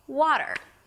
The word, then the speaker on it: water: It's not water, water, der, der, der. So just try that, der, wa, and then der, der, water. Primary stress on the wa, and then a small der, water.